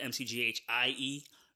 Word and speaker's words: McGhie: They called me Spooks, McGhie, Spooks. They called me Spooks. And I added the McGhie as my surname. My last name is McGhie. And you know, the way I spell it, McGhie, is the Caribbean way.